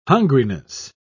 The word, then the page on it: hungriness: British English